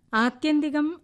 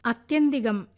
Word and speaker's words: ആത്യന്തികം: ആത്യന്തികം അർത്ഥം അവസാനത്തേത് എന്നൊക്കെയാണ്. അത് ഉച്ചരിച്ച് കേട്ടത് അത്യന്തികം എന്നാണ്.